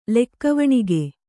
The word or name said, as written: ಲೆಕ್ಕವಣಿಗೆ